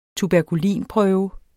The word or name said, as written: tuberkulin